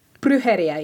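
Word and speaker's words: brujería: It occurred to me whilst listening to your episode on, is it Brujeria? – Brujeria, yes. – Brujeria. Yeah, Brujeria. There we go.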